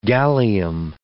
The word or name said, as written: gallium